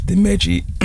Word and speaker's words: Dimeji: So if you just search Dimeji Iwosho, so Delta Igloo, Miami, Echo, Juliet Igloo.